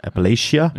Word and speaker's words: Appalachia: That's the, I think it's pronounced Appalachia. – Appalachia. – Yeah. Yeah, I always got it wrong.